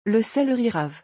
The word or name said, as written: Le celeri-rave